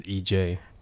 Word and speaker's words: EJ: it's actually not an initial. It's just the pronunciation of my Chinese name is EJ. – Oh, I see. – It would just be easier to introduce myself as EJ.